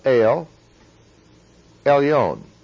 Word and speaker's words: El Elyon: It is the word El, or El, Elyon, long O, El Elyon.